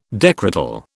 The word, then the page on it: decretal: British English